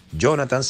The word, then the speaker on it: Jonathan: Si te quieres comunicar personalmente conmigo, mi username o nombre de usuario en Instagram es Jonathan Montenegro. Jonathan se escribe con TH, J-O-N-A-T-H-A-N, Montenegro.